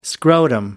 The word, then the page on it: scrotum: American English